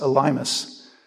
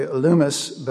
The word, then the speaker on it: Elymas: Now, up until the other day, I've always pronounced this Elymas. So I'm trying to change that. It's really Elymas, but there you go.